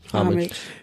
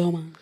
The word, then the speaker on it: homage: You about to say homage. – Yeah, I was about to say it like that. Yeah, and that's not how you say it. – You can say it like either way.